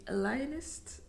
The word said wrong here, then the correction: Alienist: Alienist, ja, ik heb hem nog niet gezien.